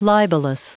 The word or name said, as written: libellous